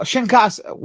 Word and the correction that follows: Kinshasa: whatever, whatever it's called. How do you pronounce it? Anyone? – Kinshasa.